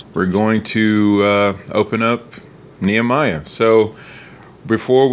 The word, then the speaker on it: Nehemiah: We are in Nehemiah is the actual way to pronounce it.